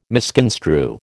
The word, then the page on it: misconstrue: American English